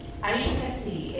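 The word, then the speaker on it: Ait-Kaci: Aitkaci. It's spelled A-I-T hyphen K-A-C-I.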